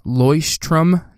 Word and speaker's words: Leuchtturm: The brand is pronounced Leuchtturm. Leuchtturm 1917.